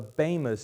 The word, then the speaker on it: Bema: Now, that's sometimes called the Bema seat, because that's the Greek word, Bema. It's the B-E-M-A, long E.